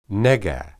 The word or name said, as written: nega